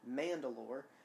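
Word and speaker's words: Mand'alor: These principles are primarily held and led by a single leader of the people known as Mandalore, and that's spelled M-A-N-D apostrophe A-L-O-R. So it sounds exactly like the planet name, but it's mm-hmm. spelled a little different.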